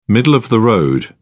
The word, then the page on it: middle-of-the-road: British English